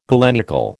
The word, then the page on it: galenical: American English